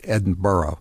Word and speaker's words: Edinburgh: It's spelled Edinburgh, but it's really pronounced Edinburgh.